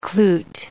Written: cloot

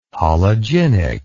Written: hologynic

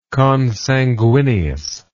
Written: consanguineously

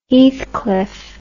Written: heathcliff

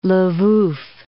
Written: Lwow

